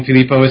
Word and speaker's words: Filippo: And my name, Filippo, is spelled F-I-L-I-P-P-O.